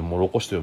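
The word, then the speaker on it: 唐: とうと書いてもろこしと読むんでしょまあ、このとうの字で、くだ、しらぎ、しらぎかな僕、実態はくだらの人だと思うんですけどね。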